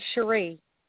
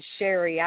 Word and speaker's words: Cherie: Well, actually, it's the way my name is spelled, C-H-E-R-I-E, so yeah. – A lot of people mistake it. I mean, as Sherry, I've had that happen my whole life, so I just go with it, you know.